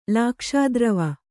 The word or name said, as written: ಲಾಕ್ಷಾದ್ರವ